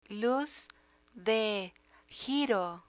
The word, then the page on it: luz de giro: Spanish